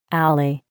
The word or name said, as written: alley